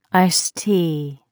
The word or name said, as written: iced tea